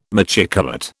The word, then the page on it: machicolate: British English